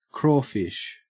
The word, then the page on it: crawfish: Australian English